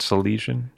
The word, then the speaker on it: Silesian: And you have Silesian roots. Am I saying that right? Silesian? – Silesian, yeah.